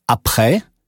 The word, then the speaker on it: après: Ä wie in après wird ausgesprochen wie ä in Ärger.